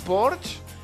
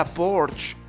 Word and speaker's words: Porsche: Tal dia com avui, ens van deixar Carles I d'Anglaterra, el van decapitar, Ferdinand Porsche, ja ho saben, es pronuncia Porsche i no Porsche, o Alfonso de Borbón, que va perdre el cap en un accident d'esquí.